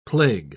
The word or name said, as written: plague